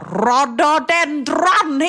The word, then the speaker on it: rhododendron: Och det var många som bara, han uttalade ju helt fel. Och med en gång jag såg det här så jag bara, rhododendron heter det faktiskt.